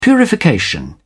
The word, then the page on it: purification: British English